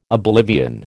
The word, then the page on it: oblivion: American English